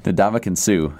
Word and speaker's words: Ndamukong Suh: Tyrann Mathieu, and I can't say his name ever, and this is even worse, Ndamukong Suh.